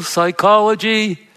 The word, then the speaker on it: psychology: Now, psychology comes from the Greek word psychos, In Greek, you pronounce, there's no silent consonant, so you pronounce the P. In English, we drop the sound of the P.